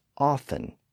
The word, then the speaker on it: often: Now, in most dialects, the T sound also disappeared in the middle of a word when it followed an F sound, like in the words often and soften. So again, those words are spelled with a T today, even though it's usually silent.